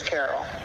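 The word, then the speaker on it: Carroll: And, And my family's last name is Carol.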